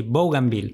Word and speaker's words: Bougainville: Cuando J me contaba en realidad sobre los viajes de Bougainville, ¿lo digo bien? – Sí, Bougainville. – Bougainville. – Muy bien.